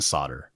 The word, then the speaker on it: solder: Man, I'm too lazy even to pronounce all the letters in solder. – I know. I always have like a moment where I have to. – There's an L in there somewhere.